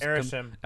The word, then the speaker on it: Arishem: Arishem.